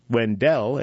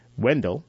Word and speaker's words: Wendell: A train depot came in 1906, and porters would call out the town's name, enunciating the name that gives the town its current pronunciation, Wendell, and not Wendell.